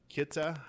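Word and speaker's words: Khitai: They found him in a jungle in Kita, is how you pronounce the country.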